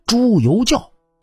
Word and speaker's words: 朱由校: 那您如果这么读的话，肯定就是大错特错了，因为“ 朱由校” 这个名字最后一个“ 校” 字应该读成“ 教”。朱由校本名应该是朱由教，那么为什么要读“ 教” 呢？